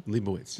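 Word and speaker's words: Leibovitz: Leibowitz.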